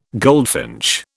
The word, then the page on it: goldfinch: British English